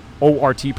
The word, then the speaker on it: Wort: We have a link directly to the our page, and of course, use promo code the Wort, WORT, pronounced Wort, spelled Wort, for your 10% off your order of Brewbag.